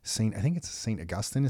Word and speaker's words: St. Augustine: But in St. Augustine is how you pronounce it, just south of Jacksonville there.